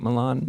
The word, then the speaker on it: MeLean: Is that right, MeLean? – MeLean. – MeLean. – I like how you say it, though. – Okay. – MeLean. – MeLean. I went French instead of Latina. – It is French.